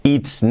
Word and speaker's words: it's: Primero ese it's, no es it's, la i como una e, letra clave. It's, it's, it's. Importante que la t la marquemos, que no digamos es, no.